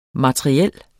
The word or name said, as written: materiel